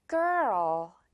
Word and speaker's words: girl: 뭔가 한 글자 같은 걸이 아니라 지금 여기에 삽 발음이 나와야 되다 보니까 girl. 이렇게 약간 두 글자라고 내가 생각을 하면서 가면 훨씬 더 진짜 발음에 가까워져요. girl, girl. 오케이. Okay. 이렇게 삽 같은 이혀 발음 기억해 주세요.